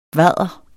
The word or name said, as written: bvadr